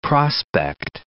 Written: Mount Prospect

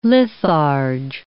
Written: litharge

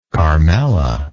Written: carmela

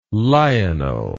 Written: lionel

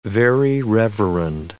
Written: Very Reverend

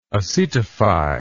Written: acetation

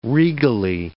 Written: regally

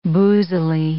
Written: boozily

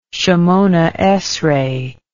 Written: Shemoneh Esreh

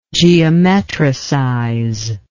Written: geometricization

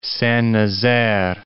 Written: Saint-Nazaire

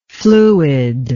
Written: fluidify

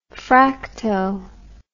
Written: fracto

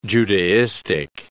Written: Judaistic

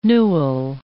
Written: newel

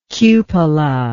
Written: cupola

